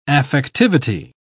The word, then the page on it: affectivity: English; British English